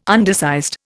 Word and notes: undersized: Indian

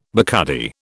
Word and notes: Bacardi: British English

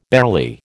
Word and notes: barely: American English

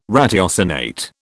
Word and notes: ratiocinate: British English

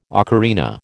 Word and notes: ocarina: American English

